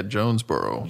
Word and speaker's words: Jonesboro: Yeah, Jonesboro.